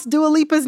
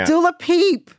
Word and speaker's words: Dua Lipa: And like, she never learned how to pronounce Dua Lipa's name. You know what I'm saying? – Yeah. – Dula Peep.